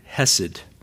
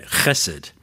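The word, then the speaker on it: chesed (חסד): The word there is the Hebrew word hesed, or to say it right, chesed.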